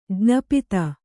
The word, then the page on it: ಜ್ಞಾಪಿತ: jñāpita